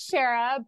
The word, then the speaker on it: Shera: It is Shera, but there are times I like to pull the Shera card and have superpowers, so no worries.